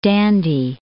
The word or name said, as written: Indy, d'